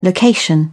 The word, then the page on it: location: British English